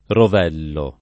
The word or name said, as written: rovello